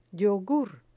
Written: yogur

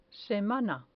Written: semana

